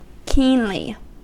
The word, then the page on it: keenly: American English